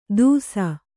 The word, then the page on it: ತುಸ: Kannada